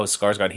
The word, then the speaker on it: Skarsgård: Oh, Skarsgård.